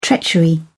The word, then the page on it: treachery: British English